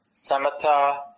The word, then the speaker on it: Samatha: Samatha and vipassana are the way you pronounce the Pali word.